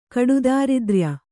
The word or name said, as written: ಕಡುದಾರಿದ್ರ್ಯ